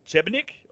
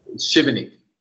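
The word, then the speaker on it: Šibenik: The next one was che- Chebnik or Sebenik. Chebenik.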